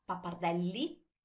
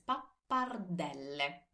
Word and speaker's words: pappardelle: It's not pappardelli, it's not pappardelle, because we always pronounce all our vowels, so it's pappardelle.